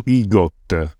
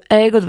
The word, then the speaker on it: EGOT: Quello che viene chiamato IGOT. – IGOT, sì. Che io dico EGOT perché Emmy si dice Emmy, quindi lo pronuncio in...